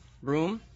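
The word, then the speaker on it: Bruttenholm: And Brunholm, Broom, as it's pronounced,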